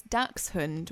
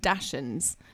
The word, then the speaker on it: Dachshund: It's Dachshund, which means badger dog in German, but a lot of people call them Dachshunds. So Dachshund is kind of like an acceptable way of pronouncing the it in an American or English way.